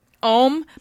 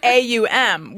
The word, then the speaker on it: Om: We om, but you pronounce all three letters of it AUM, which was like I thought it was just OM. That was the O-H-M.